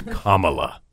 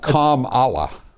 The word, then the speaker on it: Kamala: No, that was it. Comma. Like the punctuation mark. Comma. Comma la. – Well, how about calm Allah? – Nope.